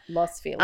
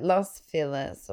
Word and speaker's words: Los Feliz: I'm so glad you said that because I don't know how to pronounce Los Feliz. – It's called like Los. Like Los Feliz or Los Feliz. – F-E-L-I-Z.